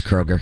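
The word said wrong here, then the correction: Kroeger: Kroger. Oh, all right. Oh, all right. – It's Kruger. – That's Kruger. Him.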